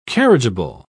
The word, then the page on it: carriageable: British English